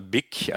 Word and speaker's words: Vic: Habe ich das richtig ausgesprochen? Nicht Vic auf Föhr, sondern in Vic in Katalonien. – Ja, oder Big. Also die Spanier machen ja so dieses ja, V oft zu dem B, ja.